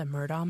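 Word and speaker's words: Murdaugh: The Murdaw Murders. They say Murdaw, but it's spelled Murdaw.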